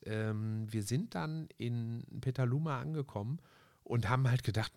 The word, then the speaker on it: Petaluma: Ich vertue mich immer mit, dem, mit der Aussprache des Namens. Ich dachte Pentaluma, aber es ist Petaluma. Petaluma.